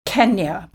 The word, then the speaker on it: Kenya: And that's where I was conditioned into saying Kenya, because that is how it's said now.